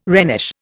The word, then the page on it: Rhenish: Indian